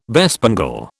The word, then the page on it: bespangle: British English